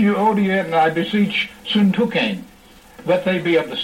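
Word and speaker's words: Syntyche: So he says, I want to say a word to Euodia and Syntuchane, or Syntuchi, as some have pronounced it, which is incorrect.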